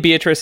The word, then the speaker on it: Beatrice: And the name Beatrice is derived from the idea of the beatific vision. – Rice. – What? – From beating rice. It's if you say it properly, it's beat rice.